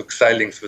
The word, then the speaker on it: Xilinx: Also Xilinx wird es ausgesprochen, gut.